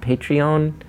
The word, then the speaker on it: Patreon: That's why something like, I'm so fascinated by, is it pronounced Patreon? – Yeah, yeah.